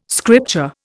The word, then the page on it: scripture: Indian